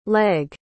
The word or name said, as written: legge